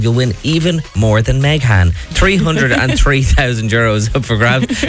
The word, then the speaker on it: Meghan: I say Meghan because it's spelled M-E-G-H-A-N.